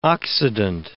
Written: Occident

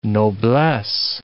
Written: noblesse

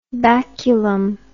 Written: baculum

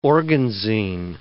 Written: organzine

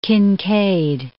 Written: Kinkaid

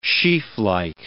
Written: sheaflike

